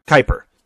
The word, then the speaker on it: Kuiper: Kuiper.